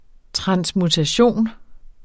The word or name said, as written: transmutation